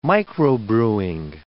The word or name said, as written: microbrewing